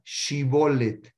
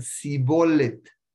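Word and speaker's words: Shibolet (שיבולת): Entonces le decían, dame, les decían una palabra, los probaban como, le decían, dime la palabra Shibolet. Shibolet en hebreo quiere decir una corriente de ajo. Y ellos, eh, los que decían shibolet y no la podían pronunciar, decían sibolet. Entonces los agarraban y los, ¿cómo se llama?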